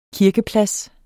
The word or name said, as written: kirkeplads